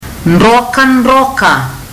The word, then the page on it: drokadroka: fiji